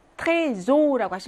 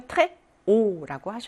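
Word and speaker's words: très haut: 트레조라고 하시면 안 되고요. 트레오라고 하셔야 됩니다.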